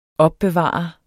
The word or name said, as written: opbevare